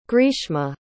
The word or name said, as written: greeshma